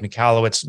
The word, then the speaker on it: Michalowicz: Well, uh, my name is Mike Michalowicz.